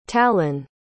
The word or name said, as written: talan